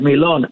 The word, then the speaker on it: dormilón: Dormilón. I send it to you. – Yes. – It's got an accent on the O. And, Don Perignon and all those drinks that have a D-O-N in the front.